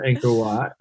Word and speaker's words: Angkor Wat: I've got Angkor Wat. Is that how you pronounce yeah. it? On my list. – Okay. Yes. – The wonder of the world, Angkor Wat.